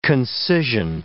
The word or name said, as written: concision